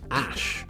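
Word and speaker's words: Æ: And the AE is pronounced Ash, then the A-12.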